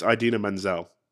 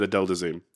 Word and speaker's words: Idina Menzel: It's Idina Menzel. And he said Adele Dazeem.